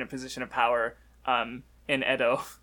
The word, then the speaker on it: Edo: Also, I notice you keep pronouncing it Ito. Wouldn't it be Edo? – Yeah, probably.